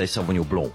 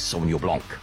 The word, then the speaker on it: Sauvignon Blanc: You see, if you're French, you say Sauvignon Blanc. I'm not French. I'm South African. And the correct way to pronounce the grape in South Africa is Sauvignon Blanc.